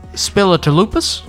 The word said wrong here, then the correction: Spilotopoulos: No, that's not it. Spilotopoulos.